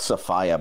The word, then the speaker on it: Sophia: It's pronounced Sophia, by the way. – Is it actually Sophia? – Yeah. – I did not know that. I've always thought it was like Sophia. – Yeah, I think everyone says. – It's spelt that way, but she doesn't pronounce it that way.